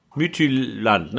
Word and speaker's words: Midtjylland: Dann Red Bull Salzburg Mittwoch, Frühspiel gegen Lok Moskau, Real gegen Donetsk, Inter gegen Gladbach, Bayern gegen Atletico, Man City gegen Porto, Midland, nein, wie wird das richtig ausgesprochen, Bennett? Midtjylland, ne? – Ja.